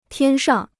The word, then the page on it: 天上: tiān shàng